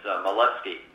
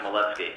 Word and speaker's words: Malevsky: The first part of that hard-to-pronounce name is Malevsky. It's Mike Malevsky, and I'm president of the school board here in Mat-Su.